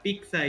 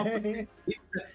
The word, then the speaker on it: pizza: Sí, pero siempre toda mi familia siempre decía pizza, entonces a mí sí me sacaba de pedo, ¿verdad? En la escuela, de que decían la pizza, y yo decía, no, pizza.